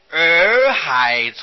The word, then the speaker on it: 洱海: 观世音菩萨现的比丘身很多的，有一部书叫做《洱海丛谈》，耳朵的耳旁边加过三点水那个耳，音是相同的，叫做念作儿子。洱海啊，就是现在的昆明池，云南的昆明池，从前叫做洱海啊。这部书是云南人做的了，因为靠近洱海啊，所以它的书名就叫做洱海丛谈。